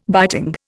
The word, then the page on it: biting: Indian